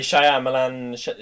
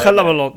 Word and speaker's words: Shyamalan: inte för att vara rasistisk, men hur fan uttalar man hans efternamn? – Ja, det är Shyamalan, Shyamalan, alltså det är Shyamalan.